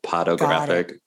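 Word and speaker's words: Podographic: P-O-D? – Is it P-O-D-O graphic, like pornographic, but potographic.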